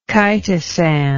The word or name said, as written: chitosan